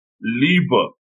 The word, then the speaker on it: Liebe: It's pronounced Liebe, which is German for love.